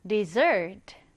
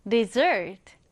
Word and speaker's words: dessert: 사막은 desert인데, 후식이나 디저트라는 의미의 단어는요, 뒤에 강세가 들어가서 dessert, dessert. 좀 다르죠?